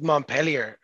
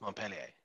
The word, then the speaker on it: Montpellier: Well, you just said Montpellier, and it's pronounced Montpellier.